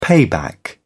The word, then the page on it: payback: British English